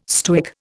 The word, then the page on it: Stoic: Indian